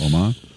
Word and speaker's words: Oman: Oman, is that how you pronounce the country? – Yes. – Oman.